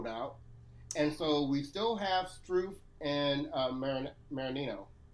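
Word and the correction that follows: Mannarino: Manarino. – Make sure I say it. Say his name right.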